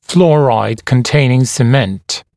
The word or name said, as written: fluoride containing cement